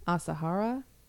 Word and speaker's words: Asahara: Shoko, S H O K O, Asahara, A S A H A R A.